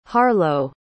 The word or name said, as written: harlowe